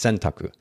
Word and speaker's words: sentaku: The word is sentaku. It's pronounced sentaku.